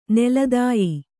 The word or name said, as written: ನೆಲತಾಯಿ